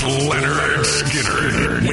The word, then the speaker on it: Lynyrd Skynyrd: It's pronounced Lynyrd Skynyrd with Hairball tonight.